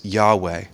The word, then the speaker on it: Yahweh: As best we know how to pronounce it, it's Yahweh. But it doesn't have any vowels in it within the Hebrew language.